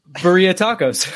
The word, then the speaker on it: birria: He said, make sure you get some Birria Tacos.